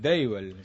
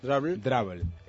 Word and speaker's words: Drabble: Uno de ellos es Drabble, se pronuncia así, del cual habla. – Drabble.